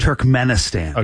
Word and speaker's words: Turkmenistan: Turkmenistan. – Is that how you say it? Turkmenistan. – Okay.